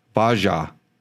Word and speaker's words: Bajaj: Ba. Jaj. Bajaj. – Yeah. – Bajaj. – Yeah. – And he spells it B-A-J-A-J.